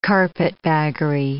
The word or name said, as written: carpetbaggery